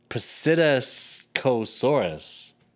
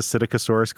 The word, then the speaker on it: Psittacosaurus: You know why you can never hear the Psittacosaurus going to the bathroom? – Because of its cloacal vent? – No, the P is silent.